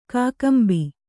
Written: ಕಕ್ಕಂಬು

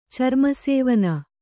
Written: ಚರ್ಮಸೇವನ

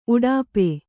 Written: ಉಡುಪ

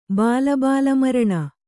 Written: ಬಾಲಬಾಲಮರಣ

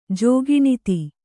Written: ಜೋಗಿಣಿತಿ

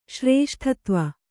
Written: ಶ್ರೇಷ್ಠತ್ವ

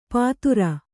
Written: ಪತ್ತೂರ

